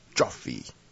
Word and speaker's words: Joffe: 沃奈仔 Adolf Joffe，像 coffee 一样，就是 C 改成 J，最后只有一个 E。